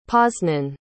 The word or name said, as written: poznan